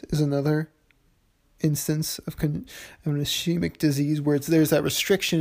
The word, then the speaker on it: ischemic: Moving on, if you go on, you can see that congestive heart failure is actually, it's normally the result of an ischemic disease. I said that wrong, but it's spelled I-S-C-H-E-M-I-C, ischemic disease, which is a lack of oxygen to tissue, to an organ or tissue.